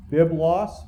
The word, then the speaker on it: biblos: The Greek word for book or books is there you can see the Greek is that you pronounce it biblos in the singular or biblia in the plural.